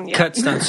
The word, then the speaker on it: Kutztown: Kutztown.